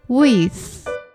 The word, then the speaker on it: with: with you 当中的 with 要把舌头放到牙齿中间才能正确发音哦。 with 下一句歌词 Hi Monster Family Please visit Taiwan again。